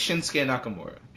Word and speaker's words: Shinsuke Nakamura: It's, it's Shinsuke Nakamura.